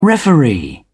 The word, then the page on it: referee: British English